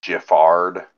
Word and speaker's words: Giffard: Yeah, 1.5 ounces of Gifford, although they pronounce it Giffard.